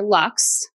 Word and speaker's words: Lux: I'm on Facebook at forward slash for the love of systems, and I'm on Instagram under my name, Kristen underscore Lux. And Kristen is spelled with a T E N like the number and Lux like luxury.